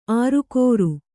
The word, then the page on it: ಆರುಕೋರು: ārukōru